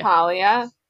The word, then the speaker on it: Thalia: How do you how do you pronounce the name of the daughter of Zeus? Talia or Thalia? – Talia. Thalia. Gosh.